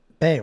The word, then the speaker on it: Bea: Bay or B? – Aisling B. – Aisling B, yeah. It's spelled B-E-A. – Right. – But it's B.